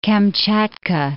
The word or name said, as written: Kamchatka